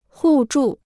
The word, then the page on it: 互助: hù zhù